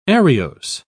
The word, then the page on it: ariose: British English